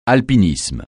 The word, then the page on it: alpinisme: francais